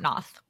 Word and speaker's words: Noth: It's not Chris Noth. It's Chris Noth. What the fuck? N-O-T-H. – No. – Noth. – I always pronounce it Noth. – It's apparently Noth.